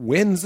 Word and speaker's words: Nguyen: And now I'm like, wow, I really hope Scotty Nguyen wins this hand. – Win. It's just pronounced win.